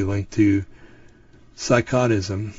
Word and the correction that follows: psychoticism: Psychoticism. There we go. I knew I was saying that wrong.